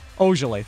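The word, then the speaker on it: Ojeleye: So far, the Milwaukee Bucks, it looks like Sammy Ojale. I can't even say his name now. Oh, yeah. I was saying it so good earlier, too. Ojale, thank you.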